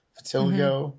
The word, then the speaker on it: vitiligo: We have a Barbie that has, how do you pronounce this? Is it vitiligo? Vitiligo? – Mm-hmm. Yeah, vitiligo.